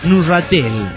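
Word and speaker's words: Neuchâtel: Dijeron el nombre del cantón donde resido, pero se pronuncia Nurratel. – Nurratel. – Aparte francesa. – Sí. – El Nahuachatel es Nurratel. En perfecto francés.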